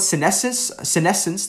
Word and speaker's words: senescence: So number one, senescence, that actually, sounds like the word senile.